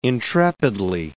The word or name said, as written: intrepidly